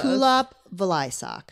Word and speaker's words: Kulap Vilaysack: Okay, so first of all, am I pronouncing your name right? Dame Judy Dench. – It's close. It's close. It's close. It's very close. – Kulap Vilaysack. – Yes.